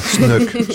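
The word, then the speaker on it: Snoek: Il y a un catalogue bilingue français-anglais de l'exposition édité par les éditions SNUK. Comment vous prononcez ? S-N-O-E-K. Hein. SNUK, SNUK.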